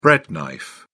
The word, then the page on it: bread knife: British English